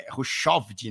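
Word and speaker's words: Hushovd: Tem um, né, que é o Thor Rushovd, né, que seria o certo. Eu Show. – falava Rushvo, ouvia Rushvo. E era Rushovd, o cara já parou e tal, foi campeão mundial, ganhou um monte de etapa de tour e tudo mais, entre outros.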